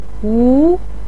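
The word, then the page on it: wu: tone 2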